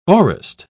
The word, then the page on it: aurist: British English